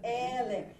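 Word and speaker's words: Ele (אֵלֶּה): Ele. The accent is on the first syllable.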